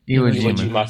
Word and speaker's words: Iwo Jima: Releases a fan map, Iowa Jima. – Yeah, I'm sorry for pronouncing that wrong. – Iwo Jima.